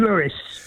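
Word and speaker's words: Louis: But Tim, Satchmo pronounced his name Lewis.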